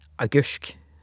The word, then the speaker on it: agurk: Eksempler er ordet agurk, som uttales agursk.